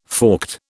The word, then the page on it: forked: British English